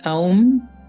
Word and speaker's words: Om: Entonces, son, en realidad, la pronunciación correcta es Aum, pero todo lo acortan, entonces quedó como Om.